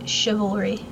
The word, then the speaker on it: chivalry: Or however the fuck you pronounce that. Chivalry? – Chivalry. – Thank you. Don't be putting V's near L's.